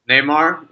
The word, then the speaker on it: Neymar: Neymar, yeah.